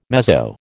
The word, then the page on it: mezzo: American English